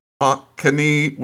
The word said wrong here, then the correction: Waunakee: Wisconsin. Waunakee. I'm very sorry.